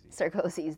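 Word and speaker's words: Sarkozy: Sarkozys, the French people.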